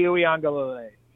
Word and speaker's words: Uiagalelei: DJ Uiagalelei. – Now spell it. – All right. U-I-A-N-G-A-L. E-I-L-A, Uiagalelei.